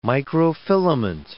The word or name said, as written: microfilament